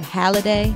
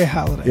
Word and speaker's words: Halladay: Halliday. Is that how you say it? – Yes, okay. Roy Halladay.